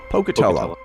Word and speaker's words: Pocatello: Am I pronouncing that right? I'm probably mispronouncing it. – Pocatello.